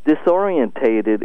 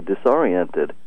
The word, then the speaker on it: disoriented: Disorientated is ridiculous because you just use an extra syllable. It's supposed. I would say disoriented. And I keep reading disorientated.